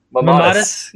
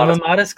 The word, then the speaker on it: mammatus: Mammatus. – Yeah, the Mammatus clouds.